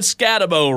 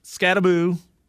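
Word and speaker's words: Skattebo: Oh, you've said Scatabo wrong. Scataboo, Scatabo. I'm fed up with this pronunciation of the guy who's now injured. – So just think the like Malibu rum, Scataboo.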